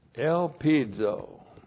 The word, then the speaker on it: elpizo: The post-positive conjunction de is the conjunction of contrast, correctly translated but, and then we have elpizo. Elpizo. Looks like that in the Greek, E-L-P-I-Z, long O.